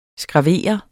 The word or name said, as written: SKRAVERE